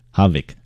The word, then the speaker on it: havoc: OC，但那个 O 不知道为什么就念成 A 的音 Havoc，它的意思叫做浩劫。